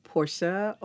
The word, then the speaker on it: Portia: Portia Ophelia Cannon.